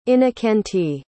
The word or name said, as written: innokentiy